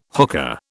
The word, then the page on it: hookah: British English